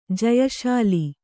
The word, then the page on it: ಜಯಶಾಲಿ: jayaśali